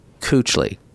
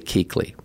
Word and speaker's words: Kuechly: So I pronounced this Panthers linebacker as Luke Kuechly when it's really Keekly.